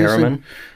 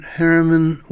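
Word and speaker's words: Herriman: Also, you know, there was just a brilliant biography of George, how do you say Harriman? – The cartoonist who did Crazy Cat.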